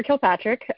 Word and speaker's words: Kilpatrick: And then next up, I'm just give you Heather McKendrick a moment to introduce yourself and about you and your campaign there. Heather Kilpatrick, sorry.